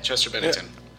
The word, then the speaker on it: Bennington: I was never that big of a fan of Linkin Park, but yeah, Chester Bennington.